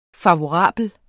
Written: FAVORABLE

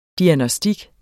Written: diagnostik

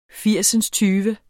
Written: firsindstyve

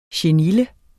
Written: chenille

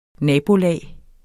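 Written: nabokone

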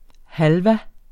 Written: halva

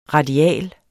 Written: radial

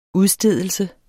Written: udstedelse

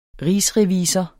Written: rigsrevisor

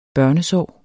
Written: børnesår

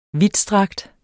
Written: vidtstrakt

